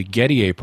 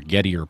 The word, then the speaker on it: Gettier: The Gettier problem, or Gettier problem, depending on which pronunciation you prefer,